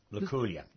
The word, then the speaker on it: Luculia: The second question is about, I think I'm pronouncing it right, Luculia? – Luculia. Mm-hmm. – Luculia. Yeah.